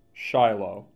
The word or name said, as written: Shiloh